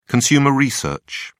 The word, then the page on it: consumer research: British English